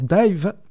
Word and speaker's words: DIIV: Alors, Dive, D-I-V-E, ça s'écrit en réalité sur la pochette D-I-I-V.